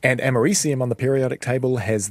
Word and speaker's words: americium: Americium, is that how you say it? – Americium, I think, is the correct pronunciation of that.